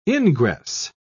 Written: ingress

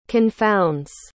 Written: confounds

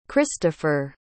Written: kristofer

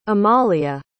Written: amalia

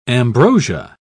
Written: ambrosia

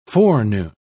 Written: forenoon

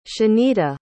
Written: shanita

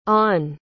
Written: ahn

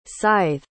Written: sithe

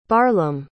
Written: barlaam

